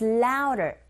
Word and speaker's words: louder: 근데 드 발음이 안 들리죠. 우, 어라는 모음 사이에 드 사운드가 꼈어요. 역시 트 사운드처럼 이 강모음과 양모음 사이에 드 사운드 역시 R 소리에 가깝게 들리게 되는 현상이죠. So louder, 틀린 발음이 아니라 원어민의 입에서 나오는 소리 자체가 louder 이렇게 들리거든요. 그렇게 발음해 주시면 됩니다.